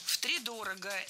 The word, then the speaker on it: втридорого: Ну, тогда я с тебя втридорого спрошу. Вот, хотя мы знаем, в истории есть немало примеров. – Втридорого – это неверно. Правильный вариант – с ударением на первом слоге. Втридорого.